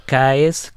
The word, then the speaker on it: Cees: o holandês Caes, que se escreve C-E-E-S, Caes uhum.